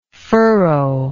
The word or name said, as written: furrowy